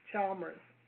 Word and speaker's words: Chalmers: Chalmers.